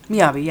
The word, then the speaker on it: Miyabi: Miyabi, yes.